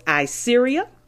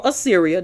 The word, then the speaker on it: Assyria: Two, by making treaties with foreign powers, which was Assyria, or Assyria, doesn't matter, both pronunciations are correct, and Egypt.